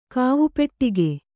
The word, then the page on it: ಕಾವುಪೆಟ್ಟಿಗೆ: Kannada; kāvupeṭṭige